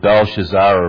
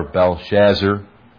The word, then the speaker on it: Belshazzar: The first 16 verses, for those of you who are listening on the internet, reveal the feast of Belshazzar or Belshazzar. I guess they're both pronounced correctly, if there's such a thing.